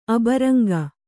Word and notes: ಅಬರಂಗ: abaraŋga

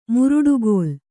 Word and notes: ಮುರುಡುಗಲ್ಲು: muruḍugallu

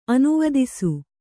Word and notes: ಅನುವಿಧಿಸು: Kannada

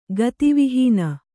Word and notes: ಗತಿವಿಹೀನೆ: gati vihīne

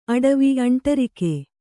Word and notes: ಅಂಟರಿಕೆ: aṇṭarike

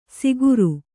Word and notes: ಸಿಗುರು: siguru

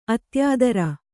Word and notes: ಅತ್ಯಾದರ: Kannada

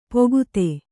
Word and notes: ಪೊಗುತೆ: pogute; Kannada

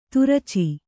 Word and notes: ತುರಚಿ: turaci

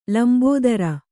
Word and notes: ಲಂಬೋದರಿ: Kannada

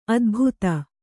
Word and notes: ಅದೆಬದೆ: Kannada

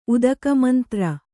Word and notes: ಉದಕಮಂತ್ರ: udakamantra

